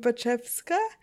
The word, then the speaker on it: Baczewska: So my name is Evelina, last name Baczewska.